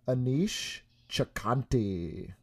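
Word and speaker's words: Aneesh Chaganty: So this week I was watching Run, directed by, I'm gonna botch the name, Anish Chakanti.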